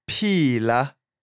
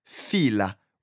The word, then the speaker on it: phila: PH is pronounced p, not f like in English. An example is the word for alive, phila, and the word well, philile. So as long as you don't pronounce the word fila when you see the letters p and h.